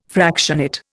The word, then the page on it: fractionate: Indian